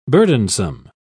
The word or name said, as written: burdensome